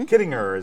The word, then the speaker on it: Kittinger: Kittinger is actually how that is pronounced.